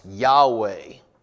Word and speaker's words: Yahweh: It is most oftentimes pronounced Yahweh. So